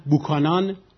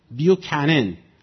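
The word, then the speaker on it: Buchanan: یا فرض بفرمایید بوکانان نوشتن، بوکانان، خب این بیوکنن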